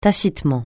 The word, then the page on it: tacitement: francais